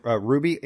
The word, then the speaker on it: RWBY: It's a series called Ruby, and it's spelled RWBY.